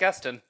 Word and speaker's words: Gustin: Instead of fucking dealing with Ezra Miller and all of his bullshit, just get Grant Gustin. Gustin? Is that how you say his name? – Yep, Grant Gustin.